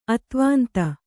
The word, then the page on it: ಅತ್ವಾಂತ: kannada